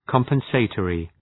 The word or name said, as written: compensatory